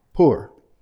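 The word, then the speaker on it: poor (πῦρ): The Greek word translated fire is, not surprisingly, pur. You would pronounce it pyre, P-Y-R-E. The Y is pronounced U, poor.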